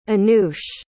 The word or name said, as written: annoush